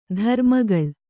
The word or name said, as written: ಧರ್ಮಗಾಳೆ